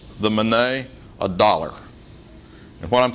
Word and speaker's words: mene: Daniel pronounces the first word, mene. Pronounced mene, the word is a noun that means mina.